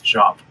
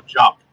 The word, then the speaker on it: Diop: Speaking of Titans, Anna Diop shows off Starfire's updated season 2 hairstyle. – I think it's Jop. – Diop. – No, Jop. – Yeah. Jop. Okay. – Like pronounce it like J-O-P.